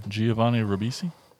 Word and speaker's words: Giovanni Ribisi: I can never say his name right. Govini Giovanni Ribisi. – There you go.